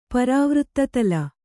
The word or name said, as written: ಪರಾವೃತ್ತತಲ